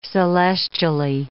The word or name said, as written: celestially